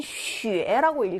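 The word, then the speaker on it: 쉬에 (雪): 자, X 뒤에 지, 치, 쉬 뒤에 나오는 유는 뒤에 유땡땡 발음이 난다고 했었습니다. 그래서 얘는 쉬에라고 읽으셔야 맞죠?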